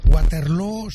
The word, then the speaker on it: Waterloo: Pues no lo es, desde luego, pero ha hecho mucho daño en la pronunciación de Waterloo, que se pronuncia igual que Feijóo.